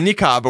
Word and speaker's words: niqab: The niqab or whatever. – Oh, God, the hijab is good. You had hijab down. You wanted to upgrade. – How do you say it? – Niqab.